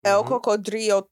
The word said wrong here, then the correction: El Cocodrilo: Did I say it right, Eden? – You tried. – El Cocodrilo.